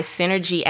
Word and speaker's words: SYNRG: So while you ponder on that, this episode of Inspired Conversations is actually sponsored by Synergy App. That's spelled S-Y-N-R-G.